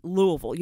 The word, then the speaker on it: Louisville: Do you say Louisville or Louisville? – Absolutely Louisville. You have to say it as if Louisville. – You have mashed potatoes in your mouth.